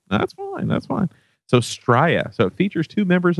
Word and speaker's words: Stria: Stria, S-T-R-I-A. – Stria, sure.